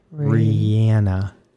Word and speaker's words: Rihanna: Rihanna. – Woo, you did it. Because I'm thinking, not Rihanna, not Rihanna.